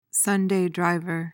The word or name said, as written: sunday driver